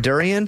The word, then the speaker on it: durian: Will you be buying your way out of it, or are you going to eat the Dorian? – I will eat it. – Oh, okay. – Wow. – Is that how you say it? A Dorian? A durian? – Durian.